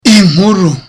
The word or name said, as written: inkuru